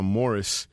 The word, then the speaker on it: Maurice: Los hermanos Gibb, Barry, Robin y Morris, o Maurice, pero ellos lo pronunciaban Morris, ahí en Lonely Days.